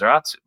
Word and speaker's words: Zaratsu: It used to be called Salaz, but when pronounced in Japanese and then brought back to English again, it becomes Zeratsu.